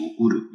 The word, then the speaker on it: うる: 如果我们翻一翻字典，可以看到这个得到的得啊，加一个 lu，这个 uru 呢，实际上在字典里面是有两个读音，一个是读 uru，另外一个是读 elu。